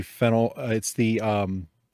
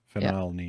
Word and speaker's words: phenylalanine: I'm pretty sure it's the phenylalanine. – Yeah, yep. Right, okay. – Phenylalanine. Yep. – That's so fat shit. Can someone spell that for me? – P-H-E-N-Y-L. Phenylalanine.